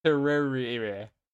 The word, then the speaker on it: Terraria: Like, say, tear. – Mm-hmm. – Tear. Rare. – Rare. – And rare. – Rare. – Yeah. – Terraria. That is how you pronounce it.